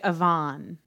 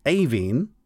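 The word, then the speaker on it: Aoibhinn: Which is A-O-I-B-H as well, but it ends with E-A-N-N. And the first one of those is pronounced Avine, so you were close.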